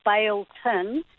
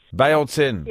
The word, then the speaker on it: Beltane: Well, actually, it's pronounced in Celtic as Baeltin. – Oh, there you go. Baeltin.